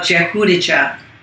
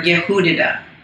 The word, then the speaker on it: Jehudijah: Now, rabbinical records, we're in B, show that Pharaoh's daughter was given a Hebrew name of Jehudijah, or Yehudida in that Hebrew pronunciation, which means Jewish.